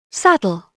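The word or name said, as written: saddle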